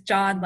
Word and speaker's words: Can: because it looks like it would be said can, but actually the C is a J sound in Turkish. So his name is John, like J-O-H-N or J-O-N kind of pronunciation. So don't call him can.